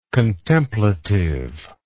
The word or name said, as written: contemplative